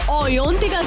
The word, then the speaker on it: Ayantika: अगर मिर्ची ओयोंतिका की दुनिया का हिस्सा बनना है तो कम से हाई टू मी ऑन माय सोशल मीडिया हैंडल्स.